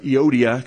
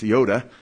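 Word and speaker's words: Euodia: Some pronounce it Iodia, because when you read in English, there's an IA at the end, but in Greek it's Ioda.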